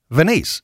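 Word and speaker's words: Venise: It's not Venice. It's V-E-N-I-S-E.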